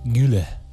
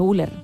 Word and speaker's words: Güler: Creo que Arda es Arda Güler. – Ah, Güler. Ah, Güler. – ¿Y cómo he dicho? Güler.